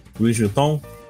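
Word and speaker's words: Louis Vuitton: Eu vou falar Luiz Vuitton, que eu não faço ideia como é que fala esse nome. É assim mesmo. Luiz Vuitton? Isso.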